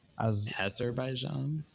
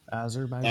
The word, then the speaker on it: Azerbaijan: Azerbaijan. – Azerbaijan.